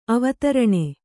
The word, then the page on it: ಅವಧಾರಣೆ: kannada